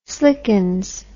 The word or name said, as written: slickens